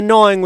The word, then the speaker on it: gnawing: And the gnawing with a silent G on the jackalope antler is the Honorable Tesla.